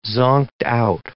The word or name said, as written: zonked-out